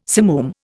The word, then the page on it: simoom: Indian